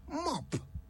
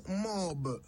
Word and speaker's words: mop: Mop. M-O-P. Mop. A simple enough word, I would have thought. Yet, it would amaze you just how often people say it wrong. It's not mob. It's mop.